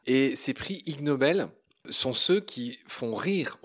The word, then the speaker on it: Ig Nobel: Je voulais m'attarder un peu sur le prix Ig Nobel. Donc ça s'écrit IG Nobel, donc il y a un jeu de mots sur le fait que ce soit ignoble.